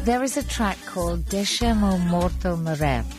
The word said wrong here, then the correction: Deixem o Morto Morrer: It's in Portuguese, it's very hard to pronounce Portuguese, but it's almost. – No, you deixem o morto morrer. We eat the vowels, so it's very hard.